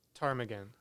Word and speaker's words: ptarmigan: So I'm gonna spell it. – Okay. – Okay. P as in Paul, T-A-R-M-I-G-A-N. – Ptarmigan. – Yes.